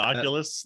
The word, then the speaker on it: Oculus: I just saw Camp yesterday brought their, is it an Oculus? Is that how you say it? – Yeah, the Oculus, the VR.